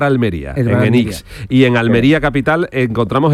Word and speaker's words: Enix: ¿Qué es, Enix o Enix? – Enix. – Enix, vale. – Lo digo bien, ¿no? – Lo has dicho bien. Terminado en X.